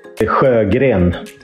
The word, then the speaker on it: Sjögren: Sjögren, Martin Sjögren. So that SJ pronunciation is a bit tricky for English-speaking persons.